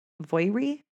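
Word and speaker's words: Voirrey: Irving was also a piano salesman turned farmer, and he lived with his wife and 13-year-old daughter, Voiry. I think that's how you'd pronounce her name. It's V-O-I-R-R-E-Y. Voiry? – Voiry.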